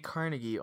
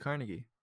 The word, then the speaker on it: Carnegie: You can find me DJ Carnegie on Instagram and my YouTube channel also DJ Carnegie.